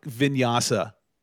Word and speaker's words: vinyasa: And if I took vinyasa versus you.